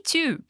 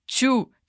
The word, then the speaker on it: too: Não se diz tchu, tipo aquela música eu quero tchu, eu quero cha, ok? Mas sim to. É o mesmo som do número 2 em inglês.